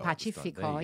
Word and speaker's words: Pacifico: And so that's why you said Pacifico. – Pacifico, yeah. It's a hard, away. It's a soft C if it's followed by an I.